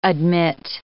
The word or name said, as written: admit